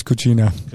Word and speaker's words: Cucina: What's the correct pronunciation? – I believe it's Cucina.